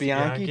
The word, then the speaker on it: Bianchi: Bianchi, just like the serial killer.